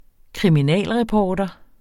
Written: kriminalreporter

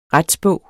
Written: retsbog

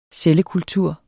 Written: cellekultur

